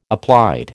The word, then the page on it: applied: American English